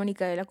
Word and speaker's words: Nicaela: De hecho, sé que mis amigos lo hacen o sea, porque al inicio se me conocieron así, yo dije, me llamo Nicaela con N. Y se escribe con N, ¿no?